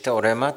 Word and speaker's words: Teorema: This production opening Wednesday night, how do you say it in Polish? – In Polish, Teorema.